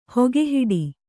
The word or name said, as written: ಹೊಗೆಹಿಡಿ